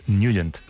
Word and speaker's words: Nugent: Hombre, siempre lo hemos llamado en el barrio Tednagent o Tednagent. – Claro, pero, yo era de Tednagent. – Pero la pronunciación correcta es Nugent.